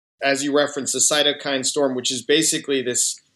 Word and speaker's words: cytokine: She has what's called, and again, I'm learning more as we go, I think everybody is, but a cytokine storm? – Yeah, cytokine storm. – How do you pronounce that? – Cytokine.